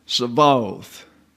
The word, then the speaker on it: Sabaoth: However, when the Holim is absent, and therefore the dot or the Holim is not there, the pronunciation changes to a V as in Victor. Therefore, the Hebrew title of the Lord is pronounced Sabaoth.